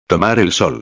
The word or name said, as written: tomar el sol